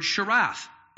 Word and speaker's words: sharath: The term minister here that is used here is the word sharath. Let me spell it for you. S-H-A-R-A-T-H. S-H-A-R-A-T-H.